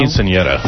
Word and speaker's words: quinceañera: It's pronounced quinceañera.